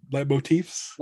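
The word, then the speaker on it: leitmotifs: You had a podcast, Lightsabers and Lightiefs. – Lightmotifs. – Lightmotifs. Yeah, I knew I was saying it incorrectly.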